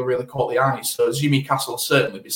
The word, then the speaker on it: Tsimikas: That said, Kostas Tsimikas, another Greek who starts with a TS, if that helps with the pronunciation.